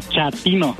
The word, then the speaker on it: chatino: Chapino. – ¿Chapino? – No, chatino. – Es como chato, ¿verdad? Chatino. – Sí, azunero como chato, chatino.